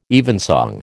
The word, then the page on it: evensong: American English